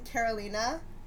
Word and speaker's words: Karolina: I'm Karolina with a K.